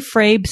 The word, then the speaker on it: Frabe: Jay Frabe, CPA. A in Frabe is long and not short vowel.